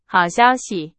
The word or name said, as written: 消息